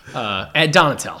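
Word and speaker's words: Donatell: Fucking no, there is no O. – Oh, there is no O. That's a bummer. You really got me all excited. Ed Donatello. So I was saying it right.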